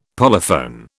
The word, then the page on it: polyphone: British English